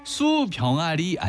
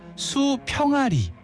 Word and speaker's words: 수평아리: 수병아리 아니고요. 수평아리.